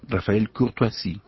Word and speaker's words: Rafael Courtoisie: vos me vas a decir si estoy pronunciando bien, Rafael Courtoisie. – Perfecto, perfecto, perfecto, tu condición políglota, queridísimo, hace que produces...